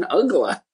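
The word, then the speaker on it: Uggla: They go, how does he pronounce his name? We go, his name is Dan Ugla.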